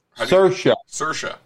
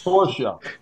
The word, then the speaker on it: Saoirse: Saoirse. – Exactly. – But it's spelled S O I R E, right? – Yeah, it's Saoirse.